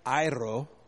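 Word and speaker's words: airo (αἴρω): I actually played it so I could say it correctly airo. It's got the tongue roll.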